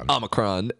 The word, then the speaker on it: Omicron: I want to say Omni. – Omni. – But it's Omicron.